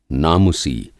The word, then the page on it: नामुसी: Hindi